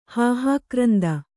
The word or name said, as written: ಹಾಹಾಕ್ರಂದ